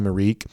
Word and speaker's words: Arik: I think that we have reached a crisis level when it comes to the pronunciation of Mr. Gilbert's first name, where you heard Kirby on Saturday call him Arik. The PA announcer calls him Arik. That's what Georgia officially listed his pronunciation as being. And yet there seems to be still a huge pocket of folks who think that Georgia's just wrong about this, that the actual pronunciation of his name is actually Eric.